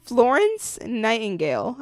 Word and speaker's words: Florence Nightingale: Florence Nightingale, a pioneer.